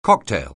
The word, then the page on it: cocktail: anglais